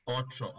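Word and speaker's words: Ochō: Ali, ako posmatramo sljedeću epizodu, Sljedeća epizoda je epizoda koja se zove Lop and Oko. I'm thinking that's how you pronounce it. Premijerno prikazana 22. septembra 2021. godine sa prosječnom ocenom 7,3. – Očo, ali da. – Očo. – Da. – Dobro, dobro, hvala vam na ispravci, to je fair.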